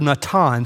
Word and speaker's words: natan: That phrase set before is the Hebrew word natan. So for my note takers, write this in your margin, N-A-T-A-N. Natan is how you pronounce that.